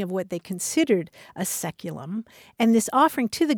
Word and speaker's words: saeculum: Anthony says the word that this caller is looking for is the Latin word saeculum, S-A-E-C-U-L-U-M, which in English is pronounced seculum.